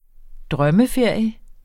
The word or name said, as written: drømmeferie